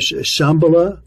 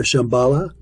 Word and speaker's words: Shambhala: What is, am I pronouncing it right, Shambhala or Shambhala? – Either can be pronounced.